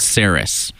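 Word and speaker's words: Cerys: And that was Tom Jones and I always pronounce her name wrong. I think it's Ceris. Ceris.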